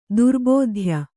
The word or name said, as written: ದುರ್ಭೇದ್ಯ